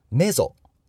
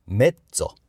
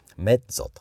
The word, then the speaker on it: mezzo: メッツォ。メッツォ。これはメゾソプラノとか、メゾフォルテとか、そんな言葉で出てきていますが、メゾではなくて、メッツォ。メッツォと。詰まるように発音しましょう。